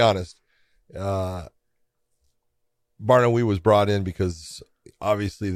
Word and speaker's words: Barnaoui: Bellator 287, Adam Piccolotti versus, how do you say, Bonawi? – Barnaoui.